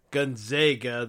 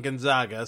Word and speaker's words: Gonzaga: So the overall number one seed being Gonzaga or Gonzaga, the proper pronunciation, but I like saying Gonzaga, so we're going to stick with that.